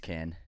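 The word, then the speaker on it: Caan: And you said James Khan. It's Can.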